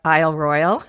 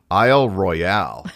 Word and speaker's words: Isle Royale: And finally, somebody very kindly corrected the pronunciation. It's Isle Royal, although I'd like Royale.